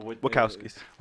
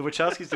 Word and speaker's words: Wachowskis: Like the way the cinematography is in these movies, like the Wachowskis, am I saying that right? – The Wachowskis. – Sorry. The Wachowskis